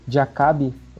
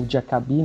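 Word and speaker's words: Diakhaby: O francês Mokhtar Diakabi, ou Diakabi, né, como preferirem a pronúncia, do Valência, ele foi vítima de racismo numa partida da La Liga em que o Valência disputava contra a equipe do Cadiz.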